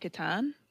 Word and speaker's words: Catan: The Settlers of Catan. – There you go. All right. See, but one thing, it's funny. So I've interviewed two people from Canada and they both say Catan.